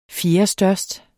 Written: fjerdestørst

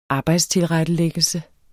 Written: arbejdstilrettelæggelse

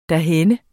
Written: derhenne

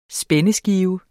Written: spændskive

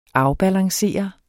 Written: afbalancere

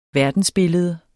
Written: verdensbillede

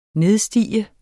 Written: nedstige

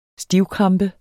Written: stivkrampe

